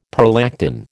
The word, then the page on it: prolactin: American English